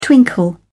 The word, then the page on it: twinkle: British English